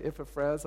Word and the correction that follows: Epaphras: I think uh, Ephras, Epaphras, Epaphras, okay, Epaphras.